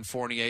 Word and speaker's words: Fournier: like Evan Fournier.